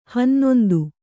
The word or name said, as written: ಹನ್ನೊಂದು